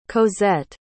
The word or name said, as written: cozette